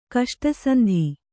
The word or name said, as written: ಕಷ್ಟಸಂಧಿ